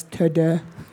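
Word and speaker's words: Teux Deux: He's like, how would the French pronounce this? So that's why it's Teudeu.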